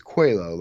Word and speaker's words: Coelho: So I pronounced this Coelho, like the Portuguese name, right? Absolutely. That is absolutely what I intended when I wrote that.